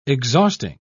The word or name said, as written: exhausting